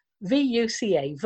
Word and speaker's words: VUCA: VUCA, VUCA. That's how I'm pronouncing it. I've never heard anybody else pronounce it.